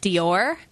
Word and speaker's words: Dior: Speaking of being off, Taryn texted me, and I'm supposed to let you know that it's pronounced Dior. Not Duar.